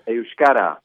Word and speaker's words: Euskara: It's called Euskara. Did I pronounce that correctly? – Hard word, isn't it? It depends. Sometimes Euskara, sometimes Euskara. It depends on the region of the Basque country you're in.